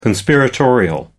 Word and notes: conspiratorial: American English

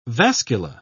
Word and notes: vascula: British English

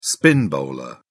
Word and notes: spin bowler: British English

